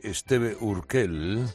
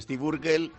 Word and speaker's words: Steve Urkel: Bueno, una encuesta de EEUU sobre personajes desesperantes de la televisión concluye que Esteve Urkel es el ganador. – Esteve Urkel, maravilloso. – Que pronuncia mal, dicen Steve Urkel, es el ganador.